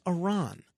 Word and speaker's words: Iran: It is Iran. It's not Iran. It's Iran. You know, it's one thing to pronounce Nova Scotia a certain way. But come on. It's Iran.